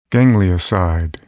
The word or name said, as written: ganglioside